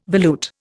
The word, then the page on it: volute: Indian